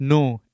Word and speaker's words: Nue: It's new. It's spelled N-U-E.